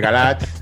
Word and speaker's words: Galaad: Se, suele decir Galat, pero bueno.